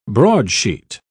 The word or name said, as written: broadsheet